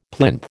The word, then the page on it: plinth: American English